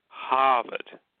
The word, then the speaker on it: Harvard: Harvard. As basically the locals pronounce it.